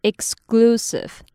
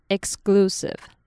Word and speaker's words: exclusive: 接下来的这个单字呢是 exclusive，这个字呢听起来好像有点难念，但其实只要注意 X 的音发成 X 的发音，还有 C 会念成 G，注意这两点就没有问题了。所以我们就可以念成 exclusive， exclusive。